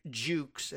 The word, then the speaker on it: Jukes: Mr. Orr and Mr. Jukes, and it's J-U-K-E-S, I'm not just affecting an upper crusty blue blood pronunciation of D-U-K-E-S. They sometimes sound like they're saying the Duke of Windsor, but it's actually D. But this is spelled with a J.